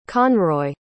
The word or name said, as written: conroy